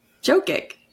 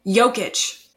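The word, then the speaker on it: Jokić: Can you pronounce that for me? – Well, his nickname is the Joker, right? I think it's... – Jokic. – Jokic? Wait, say it again? Jokic.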